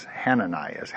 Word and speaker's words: Hananias: By the way, this has what we call in Greek a rough breathing mark over it. So it normally is spelled Hananias. Hananias. Hananias is actually the correct pronunciation.